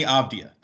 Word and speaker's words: Avdija: Denny Avdija. That's the right way to say it.